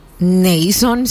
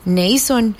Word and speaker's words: Naason: ¿Cómo pronunciarías mi nombre? Se escribe N-A-A-S-O-N. Nason, siento yo. No es así. Nason.